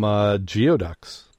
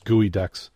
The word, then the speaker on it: geoduck: How about some geoducks? I said geoducks, gooey ducks. I know how to pronounce it. Why did I say it wrong? Because it looks like geoducks.